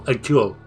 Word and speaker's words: Hercule: Sé que Hercule, Hércules, los corregís. – Hercule. – Exacto.